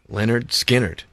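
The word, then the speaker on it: Lynyrd Skynyrd: Just, it would rhyme with Skinner. – Leonard Skinner.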